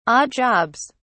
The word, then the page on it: odd jobs: British English